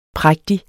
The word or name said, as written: prægtig